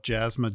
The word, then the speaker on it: Jaasma: If you want to just go to, my last name is spelled Jaasma, J-A-A-S-M-A.